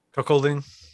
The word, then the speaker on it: cuckolding: Cuckling. What is it? Cuck? – Cuckolding. – Cuckolding. There you go.